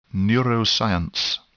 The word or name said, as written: neuroscience